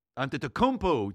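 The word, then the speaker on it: Antetokounmpo: Gianni eh, que pues es un jugador fuera de serie ante Tecumpo, ante Tocumpo, lo dije bien, fíjate nada más. Me dieron el dedo pulgar hacia arriba.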